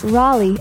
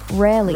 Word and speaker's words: Raleigh: And I know it's pronounced Raleigh and not Raleigh.